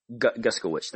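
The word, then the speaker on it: Guskiewicz: So, you know, he has Kevin, I'm going to say his name wrong, but Guskovitz or Guskovich, however you say his last name. – Guskovich. – Thank you.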